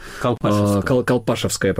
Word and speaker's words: Колпашевское: Колпашевское, правильное да, ударение.